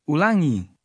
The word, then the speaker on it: ulangi: The stress is on the ending. – Ulangi.